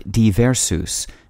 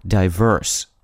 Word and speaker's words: divers: Both meant various and could be pronounced as either divers, like the plural of the noun diver or a person who dives, or diverse,